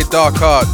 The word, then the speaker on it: Darquart: I say Darquart, but it's spelt with Q. Darquart.